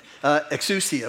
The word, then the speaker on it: exousia: The first one, I pronounce it exousia, which is spelled E-X-O-U-S-I-A.